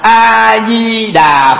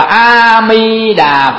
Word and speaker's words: A Di Đà: Như vậy thì chúng ta đọc A Di Đà Phật ta biết đó là Đức Phật A Di Đà ở phương Tây, chúng ta đọc là A Mi Đà Phật biết là Đức Phật A Di Đà ở phương Tây.